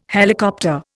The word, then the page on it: helicopter: Indian